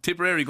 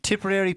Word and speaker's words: Tipperary: Yeah, it's going to, Tipperary going to take over the world. I think that's the way it's going to be. – Tipperary people pronounce it with no E.